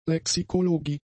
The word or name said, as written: Lexikologie